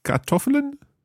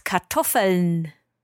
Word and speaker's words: Kartoffeln: With potatoes mit Kartoffeln? – Ja, fast. The structure was correct, but the pronunciation was a little bit off. It's Kartoffeln.